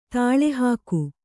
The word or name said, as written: ದಾಳಿಹೋಗು